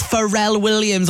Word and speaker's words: Pharrell Williams: It's Pharrell Williams on Hits Radio.